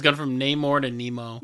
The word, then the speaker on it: Namor: There was another UFC fighter that was actually up for the main role of Namor. I'll keep butchering his name. Is it Namor? – Namor. – Okay.